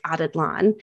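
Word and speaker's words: Atitlán: So this one was San Pedro volcano, and this was out of Lake Atitlan. Again, I'm pretty sure of that pronunciation, but I would pronounce it the same.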